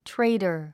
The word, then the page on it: trader: tréidər; トレイダァ